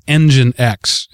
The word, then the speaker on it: Nginx: I just found out about Nginx, is how that's pronounced.